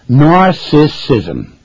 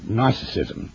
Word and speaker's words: narcissism: Narcissism. Big word, three syllables, used as a descriptive word. Narcissism.